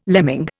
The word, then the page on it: lemming: Indian